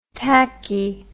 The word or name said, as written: tackey